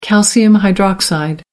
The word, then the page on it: calcium hydroxide: American English